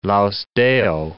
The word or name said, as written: laus Deo